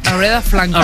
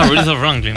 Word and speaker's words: Aretha Franklin: Y te voy a invitar por pronunciar también Aretha Franklin. – Aretha Franklin, muy bien.